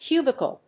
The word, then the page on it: cubicle: cu・bi・cle; kjúːbɪkl (米国英語); ˈkju:bɪkʌl (英国英語)